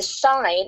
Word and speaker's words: sign: Another example is a sign, S-I-G-N. Why is a G there? Why not just spell it S-I-N-E? It's there because it's a relationship to signal, designation, resign.